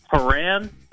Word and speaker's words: Horan: Is it Horan or is it Horan? – Horan. – Niall Horan? – Niall Horan. – Yeah. – All right, my brain wants to say Horan, so if I'm going to try to correct myself, I'm going to say Niall Horan.